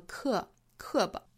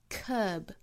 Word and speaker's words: curb: There's some natures too noble to curb，长元音紧元音的 curb 不要读成了 curb，注意嘴角要裂开，嘴角肌肉紧张发 curb。